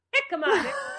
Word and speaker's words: jicama: Who knew that hemica, am I saying that right? Hemica. How do you say that? – Jicama? – Jicama. Jicama, it's quite yummy in a salad.